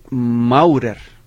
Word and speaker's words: Maurer: Se dice Maurer.